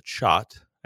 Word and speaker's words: khat: And they, in Africa, there's a leaf called chat. I believe it's spelled K-H-A-T.